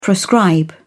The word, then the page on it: proscribe: British English